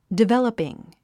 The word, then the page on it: developing: divéləpiŋ; ディベラッピン